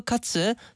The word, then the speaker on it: 카츠: 카레라이스, 계란국, 돈마호크 카츠, 치커리 샐러드, 배추김치, 그리고 체리였습니다. – 야하. – 어우, 돈마호크 카츠가 너무 맛있을 것 같은데요? – 오, 발음이 좋으시네요. 어? 카츠. – 카츠. – 그 츠 발음이 굉장히 어렵잖아요.